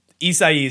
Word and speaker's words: Isai: I mean, it's definitely like, unless you speak Spanish or like know, I guess, like Spanish pronunciations, like Isai is definitely not what you would see. Like, I feel like that's a lot of like sound for just like four letters almost, you know, like nobody is gonna expect that. – It's like four syllables for four letter.